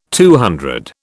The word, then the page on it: CC: British English